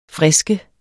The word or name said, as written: frisk